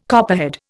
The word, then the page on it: copperhead: Indian